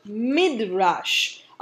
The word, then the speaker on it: midrash: A madrasa comes from the Hebrew word midrash.